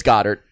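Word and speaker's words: Goedert: Gotta, is it Gordaire? Because I hear everybody else say Goddard. – It's Goddard. I'm just pronouncing it wrong.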